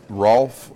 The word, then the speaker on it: Rolf: Rolf Lindgren, R-O-L-F-L-I-N-D-G-R-E-N, and I live in Middleton, Wisconsin, and I'm the Pints and Politics Director of the Republican Party of Dane County.